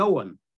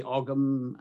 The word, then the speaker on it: Ogham: I think a sort of a standardized scholarly pronunciation seems to be Owen. But you can say Ogham.